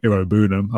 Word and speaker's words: Iroegbunam: So Tim Iroegbunam, I believe it is, the G is silent,